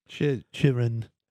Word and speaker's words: Chychrun: Chychrun trade? Wow, that pronunciation. – Chychrun. – There are not as many CHs in Chychrun as I just indicated for those spelling at home.